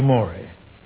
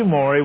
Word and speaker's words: Maury: Name is spelled M-A-U-R-Y. Matthew Morey. Now, Matthew Morey was a man of the seas.